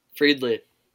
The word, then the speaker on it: Friedli: Friedley.